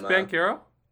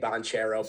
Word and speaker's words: Banchero: Is it pronounced Bancaro? – That's what the commentators were saying during the game. I think it's Bancaro. I've been saying Banchero.